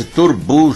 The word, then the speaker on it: Turnbull: E esse Turbu se escreve T-U-R-N-B-U-L-L.